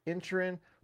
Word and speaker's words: Inisherin: Even though it's spelled Inisherin, they say in the movie Inisherin, plus Knives Out Glass Onion.